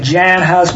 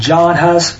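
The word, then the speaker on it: Jan Hus: It's really Jan Huss, but we pronounce it John Huss.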